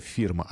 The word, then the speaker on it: фирма: Фирма. Именно вот так ударение мы ставили на последний слог. Не просто фирма, а фирма.